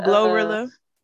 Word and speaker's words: Glorilla: That, oh, Glorilla. – Oh, is that how you say her name? – Yeah, Glorilla. – Oh, girl, I thought it was Gorilla.